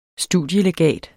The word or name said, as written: studielegat